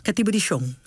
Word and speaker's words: Katibu di Son: Katibu di Son. Zeg ik dat goed? – Katibu di Son. Ja.